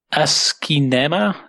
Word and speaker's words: asciinema: The second one, and I have to see if I pronounce it correctly, it's ASCII-nema. It's basically ASCII cinema combined into one word.